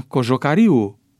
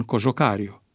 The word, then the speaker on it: Cojocariu: Astfel se pronunță Tudor Chiuariu în loc de Tudor Chiuariu, Răzvan Cojocariu în loc de Răzvan Cojocariu,